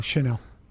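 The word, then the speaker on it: Chenal: You got Leo Chanel. I guess that's how you say his name. – Don't worry about the linebackers. No, Chanel. – Chanel? – Mm-hmm.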